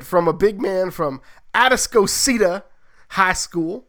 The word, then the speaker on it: Atascocita: Houston area, odd pronunciations, kind of like Guadalupe and others. That's Atascocita. – I should have practiced that one. No. Atascocita. – That's right. You got it.